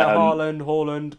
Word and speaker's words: Haaland: I'll go Haaland for now. I hope I'm saying his name right as well. – Yeah, Haaland.